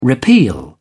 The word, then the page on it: repeal: British English